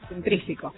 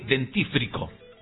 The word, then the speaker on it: dentífrico: Pero por una cuestión de fonética, suena mejor dentrífico que dentífrico. – Claro. Sí, sí, tal cual. No, lo mal está mal.